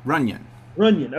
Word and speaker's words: Runion: Runion.